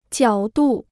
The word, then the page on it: 角度: jiǎo dù